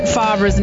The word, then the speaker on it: Favre: How do you say Brett Favre's name? – Favre. – How do you spell it? – Favre. – You spell it Favre, but you say it Favre.